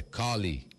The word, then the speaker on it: Collie: Ah, eu falei Corley, falei errado. É Collie, não é Corley. Oh. Não tem R, é Collie.